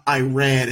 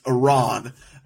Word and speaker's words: Iran: It's not Iran, it's Iran, and like all this stuff.